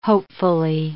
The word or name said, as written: hopefully